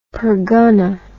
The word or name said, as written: pargana